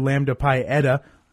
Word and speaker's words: Lambda Pi Eta: He's the professor of communication studies over at Hastings College and also the faculty advisor for Lambda Pi Eta.